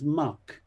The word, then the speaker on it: Mack: The local beer they were drinking was spelt Mac, M-A-C-K, but pronounced by the locals as muck. So you ask for a pint of muck, please.